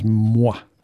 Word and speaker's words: Mngwa: It's spelled M-N-G-W-A, Mngwa, pronounced by some as Mwa.